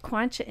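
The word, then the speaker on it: Quechua: Did I say that right? Quancha Indians? – Yes. – Yes.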